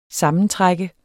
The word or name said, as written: sammentrække